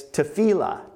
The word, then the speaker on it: tefillah: And if I was to pronounce this in Hebrew, I would say it's tefillah.